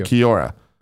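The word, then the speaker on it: Kia ora: Kiora is Maori for hello. – And it's pronounced, thank you. – Kiora. Kebab says that.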